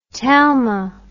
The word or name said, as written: talma